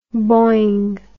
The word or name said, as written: boing